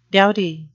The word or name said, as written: doughty